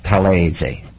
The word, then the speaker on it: Talese: The real name is pronounced Talese. T-A-L-E-S-E is Talese.